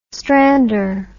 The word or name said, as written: strander